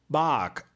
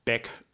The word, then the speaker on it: Baeck: Aber wer nicht aus dieser Gegend kommt, hat doch seine Probleme zu akzeptieren, dass mein Name nun mal Bark ausgesprochen wird und nicht Beck oder Beg.